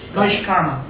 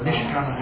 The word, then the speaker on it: Naishkarma: Naishkarma. – Yes. – N-A-I-S. Naishkarma. Or Nishkarma, however you like to pronounce it. N-A-I-S.